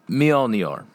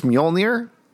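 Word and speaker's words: Mjölnir: Mjolnir. – Okay, I think it's Mjolnir.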